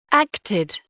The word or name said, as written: acted